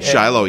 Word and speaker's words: Shiloh: Shiloh. Oh. I'm saying that right, like Shiloh. Yep.